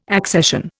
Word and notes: accession: Indian

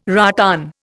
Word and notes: rattan: Indian

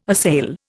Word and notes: assail: Indian

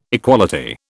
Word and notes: equality: British English

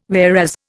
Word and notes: whereas: Indian

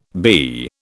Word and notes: BE: British English

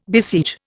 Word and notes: besiege: Indian

